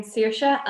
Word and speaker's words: Saoirse: Hello, I'm Saoirse, and yes, he did say it right.